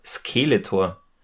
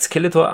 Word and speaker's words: Skeletor: Der HG Francis, der sagt nämlich immer Skeletor. Also er betont das erste E so, er betont die erste Silbe. Das erste E so ein bisschen länger, so ganz klassische deutsche Betonung.